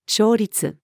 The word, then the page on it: 勝率: female